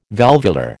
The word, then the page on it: valvular: American English